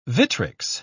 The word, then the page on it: vitrics: British English